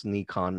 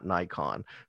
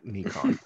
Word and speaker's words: Nikon: And it's actually pronounced Nikon, not Nikon. So just for a little, you know, now you know moment, Nikon.